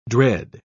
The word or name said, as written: dread